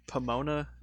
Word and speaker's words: Pomona: Where you guys are living in Pomona, right? California? Did I say that right? – Yep.